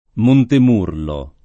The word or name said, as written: Montemurlo